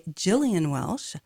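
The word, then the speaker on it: Gillian Welch: And so let's have a song by Gillian Welsh. Found out that's how you pronounce the name.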